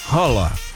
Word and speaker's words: Haaland: É que escreve Haaland, mas eu já vi entrevista, inclusive até o Ronaldo Fenômeno falou, a pronúncia certa seria Haaland.